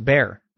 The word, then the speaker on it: Bexar: And the X in Bexar is silent, so it's pronounced Bear.